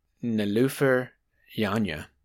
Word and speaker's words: Nilüfer Yanya: Earlier this year, he brought up this uh, album that came out earlier this year called "Painless" by an artist named Nilüfer Yanya. That's how you pronounce that.